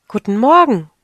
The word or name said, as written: guten morgen